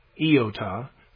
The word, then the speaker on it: iota: The word is pronounced iota, not iota or iota. It's iota.